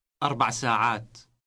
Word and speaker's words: أربع ساعات: Say 4 hours. Remember to drop the A ending. – أربع ساعات أربع ساعات.